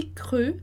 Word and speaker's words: cru: E esse cru, né? Treinar lá, porque não é tão evidente fazer o cru, o som, né? E a pronúncia.